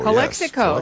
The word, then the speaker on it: Calexico: And how do you say that? Calexico? – Calexico, yes.